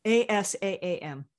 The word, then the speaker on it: Assam: Assaam.